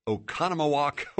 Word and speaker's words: Oconomowoc: Dennis from Oconomowoc, Wisconsin.